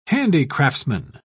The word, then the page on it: handicraftsman: British English